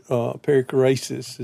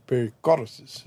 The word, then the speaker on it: perichoresis: And then eventually they took up a word, perichoresis is the proper pronunciation, or perichoresis, as they would say.